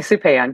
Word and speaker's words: Supan: It's actually Supan, but Supan, you can say it however you want.